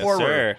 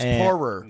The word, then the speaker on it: Poirier: We have Dustin Poirier. Is that how you pronounce it? – Yes, Poirier. – It's Poirier.